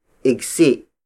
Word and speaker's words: igsi: Maikling bangko is spelled as M-A-I-K-L-I-N-G, space B-A-N-G-K-O. It means short bench. Bangko, the Tagalog word for bench, is spelled as B-A-N-G-K-O. Ikli has two other variations, namely igsi. Igsi. Igsi, which is spelled as I-G-S-I. And iksi. Iksi. Iksi, which is spelled as I-K-S-I.